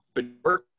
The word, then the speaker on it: Bjorkgren: so I think the pronunciation is Bjorkgren.